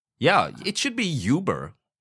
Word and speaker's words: Uber: Yes, so in English sometimes we say, oh, that thing is uber big.That also means super big. So Uber, in this case, is pronounced like that because that's how the German word stands in English.